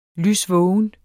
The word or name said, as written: lysvågen